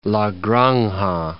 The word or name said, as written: La Granja